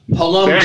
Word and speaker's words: Polomchak: Our host or our other co-host, we'll say guest extraordinaire, is Ashley Polomchak.